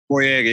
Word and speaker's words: Boyega: Boy, Boy, Boyega, Boy, Boyega, Boyega.